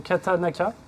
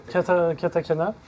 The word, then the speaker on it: katakana: tu passes en que je le prononce bien, en katanaka, katakana.